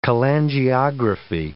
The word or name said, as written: cholangiography